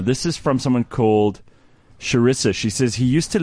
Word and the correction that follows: Carissa: Carissa. Yes. – Right. It's Carissa. I've been saying it wrong the whole time.